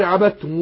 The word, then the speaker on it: عبدتم: فهنا ما اقول ايه ولا انا عابد ما عبدتم عبدتم، يبقى ادغمت الدال في التاء فنطقنا التاء مشددة قول عبدتم.